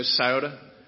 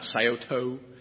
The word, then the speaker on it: Scioto: Scioto spelled with an A on the end, by the way. So we're saying it when we say Scioto. We're saying it right. Not Scioto.